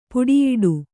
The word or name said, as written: ಪುಡಿಯಿಡು